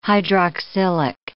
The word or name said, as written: hydroxylic